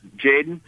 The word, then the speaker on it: Jayden: No, I'm Jaden. – Say again? – Jaden. – Okay, how do you spell it, Jaden? – J-A-Y-D-E-N.